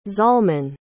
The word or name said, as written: Zalman